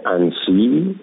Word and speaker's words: انسی: اینا تلفظ بد میکنن، به جای اینکه بگن بلیط میخواییم برای شهر انسی، این انسی رو بد تلفظ میکنن. اینا دو تا میخرم بلیط میخرم برای شهر نانسی. نانسی شمال شرقی دم مرز آلمانه، در صورت که انسی تقریبا مرکز شرقیه.